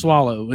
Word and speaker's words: Soileau: But the last name is spelled, it's pronounced Soileau. It's, which I know it's S O I L E A U.